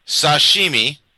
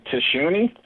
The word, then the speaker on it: sashimi: Garlic tuna sashimi. – Okay, hold on. – I know he's going to say this wrong. – I can say tashuni. – Sashimi. Tashuni. Yes. That's fish without the rice.